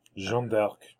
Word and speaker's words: Jeanne d'Arc: Jeanne d'Arc. – Thank you for the proper pronunciation.